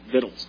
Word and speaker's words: victuals: And that's the word victuals, by the way. Amen. This is a good southern word right there, except in the south they spell it with two T's. But I promise you that's how you pronounce it, victuals.